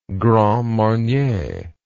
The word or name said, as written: grand marnier